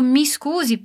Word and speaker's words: scusi: Você pode usar o mi scusi, por exemplo, para se desculpar ou chamar a atenção de alguém. Você já viu ela no episódio 6. O único segredinho da pronúncia aqui é o S mudo.